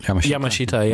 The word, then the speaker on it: Tatsuro Yamashita: So the people that were making it, even the really high-end folk, guys like, I'm going to try it, Tatsuro Yamashita? – Yamashita, yeah.